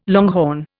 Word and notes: longhorn: Indian

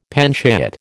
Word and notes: panchayat: American English

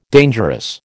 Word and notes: dangerous: American English

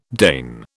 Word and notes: Dane: British English

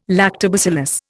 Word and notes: lactobacillus: Indian

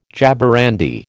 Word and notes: jaborandi: American English

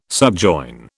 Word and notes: subjoin: British English

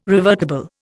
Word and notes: revertible: Indian

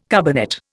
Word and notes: carbonate: Indian

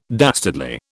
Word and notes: dastardly: British English

